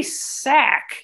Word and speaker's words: Sachz: I felt like I remember it being Harry Sachs, and it's spelled S-A-C-H-Z, but they say sack in the show. So I'm going to stick with sack, just like the singular, since that's what they say.